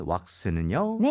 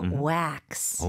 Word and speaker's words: wax: 자, 마지막으로 우리 머리에 바르는 wax는요? – 네, 영어로는 이름절로 발음하셔야 돼요. Wax. – 오. – Wax. – 지금 들으니까 너무 와도 아니고 너무 외도 아니고 그 중간으로 이어지는 느낌입니다.